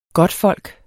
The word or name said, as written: godtfolk